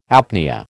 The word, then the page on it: apnoea: American English